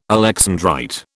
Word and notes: alexandrite: British English

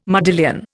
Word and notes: modillion: Indian

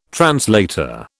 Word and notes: translator: British English

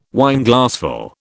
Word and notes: Wineglassful: British English

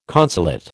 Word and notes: consulate: American English

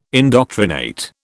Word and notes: indoctrinate: British English

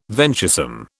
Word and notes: venturesome: British English

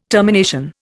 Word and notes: termination: Indian

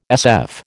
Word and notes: SF: American English

